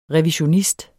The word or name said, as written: revisionist